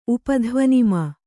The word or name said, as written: ಉಪಧ್ವನಿಮಾ